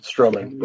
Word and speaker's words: Strowman: I'm picking, how do you say it, is it Strowman or Strowman? – Strowman, Braun Strowman.